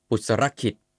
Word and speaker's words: ปุตรขิต: ปุสรคิด